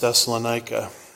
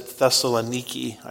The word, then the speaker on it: Thessaloniki: And it's sort of disconcerting when you travel there, you've learned your whole life you've mispronounced Thessalonica. They pronounce it Thessaloniki, I think.